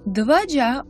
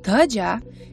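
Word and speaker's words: dhvaja: Dvaja, or sometimes pronounced Dhaja, means flag.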